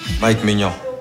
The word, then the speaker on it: Mike Maignan: Mike Maignan.